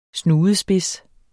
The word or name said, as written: snudespids